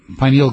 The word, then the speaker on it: pineal: You say that the pineal gland, is that how you pronounce it, pineal? – Pineal, yeah.